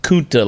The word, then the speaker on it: Kunta: And LeVar Burton corrected him and said it's pronounced Kunta, like unity, Kunta Kinte.